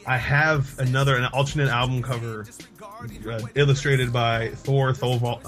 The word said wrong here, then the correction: Thorvaldsen: I can never say his name. His name is Thor, and that's all that really matters about him, because his name is Thor Thorvaldsen, and he has Thor in his name twice, which makes him better than most people.